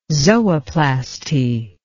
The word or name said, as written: zooplasty